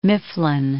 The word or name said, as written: Mifflin